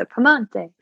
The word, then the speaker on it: Piemonte: I love Piemonte in Italy, also known as Piedmont to us in the States, but I like to say the name of the region the way the locals say it. So Piemonte.